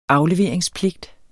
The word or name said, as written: AFLEVERINGSPLIGT